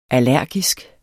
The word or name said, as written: allergisk